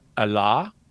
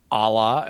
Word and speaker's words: Allah: So Allah, or Allah, as we would pronounce it with an English accent, is just the Arabic word for God.